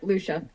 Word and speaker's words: Luscia: Yep, Luscia.